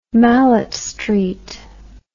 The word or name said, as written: malet street